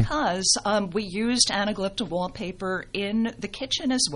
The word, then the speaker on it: Anaglypta: If you do have plaster walls, which many of you do, the anaglyptical paper. Did I say that right? That's anaglypta. – Oh, okay. Leave it to a librarian. Okay. So thank you. I stand corrected.